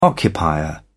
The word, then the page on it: occupier: British English